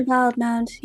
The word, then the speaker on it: Wildemount: Is it Wildemount or Wildemount? I can never know how to pronounce Wildemount or Wildemount. It's Wildemount, right? – Usually Wildemount, yes.